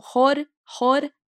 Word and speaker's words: Jorge: Sim, eu acho que, ó, a gente tem, né, esse som do R ali no meio, então a, a pronúncia, né, ali do espanhol seria Jorge. Então esse, esse Rzinho, ele é bem, bem, bem sutil. Jor, Jorge.